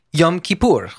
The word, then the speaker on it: Yom Kippur: Yom Kippur.